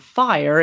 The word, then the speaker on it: FI/OR: In 2017, I think I started to pivot to something that I actually coined called FIRE. It's pronounced the same way, but it's F-I-O-R, and the O-R is optional retirement.